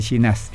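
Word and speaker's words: Llinás: Ahí me acordé, una era Verónica Linás. ¿Lo dije bien? – No. Verónica. No, sí, se escribe así, pero se pronuncia Ginás.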